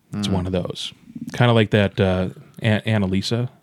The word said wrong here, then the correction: Anomalisa: Anomalisa. – Anomalisa. I always say it wrong every single freaking time.